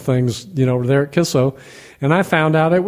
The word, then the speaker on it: Kicco: And one of the places we used to go was called Kisso. It's spelled K-I-C-C-O, and it's pronounced Kisso.